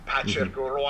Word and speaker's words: Patrick Roy: Patrick Roy, I should say, properly pronounce it.